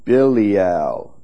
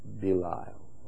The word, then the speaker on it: Belial: Another name for the devil, Belial. Some pronounce it Belial. B-E-L-I-A-L.